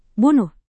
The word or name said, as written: bunuh